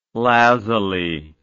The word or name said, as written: lazuli